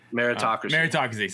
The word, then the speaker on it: meritocracy: I want to say metrocracy, but I can't say it right anyway. – Meritocracy. – Sorry, I was like, I'm missing a syllable.